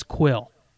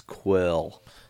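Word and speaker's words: Kuiil: And I want to say, looking at how his name is spelled, I'm going to say the pronunciation, my best guess is Quill. – Okay. Ah, that makes so much fucking sense, Quill. – Because it's K-U-I-I-L.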